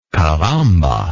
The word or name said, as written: caramba